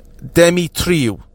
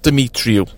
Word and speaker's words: Demetriou: Regards to the pronunciation of Mickey's name, I've been told it's a vegetarian name, so there's no meat in it. So it would be pronounced Demetriou, not Demetriou.